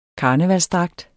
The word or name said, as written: karneval